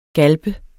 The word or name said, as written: galpe